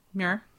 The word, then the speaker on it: Muir: Muir.